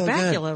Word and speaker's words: Bakula: You know, Scott Bakula, of course, rhymes with Butt Cracula.